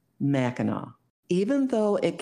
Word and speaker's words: Mackinac: The word is pronounced Mackinac no matter if it ends with an A-C or an A-W, and it means great connection to land or place.